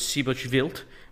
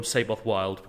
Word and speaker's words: Seyboth Wild: And I'm I'm going to once say his name the Portuguese way, which I believe is Sibochvilt, but otherwise I'm going to call him Seyboth Wild because everyone else will say that.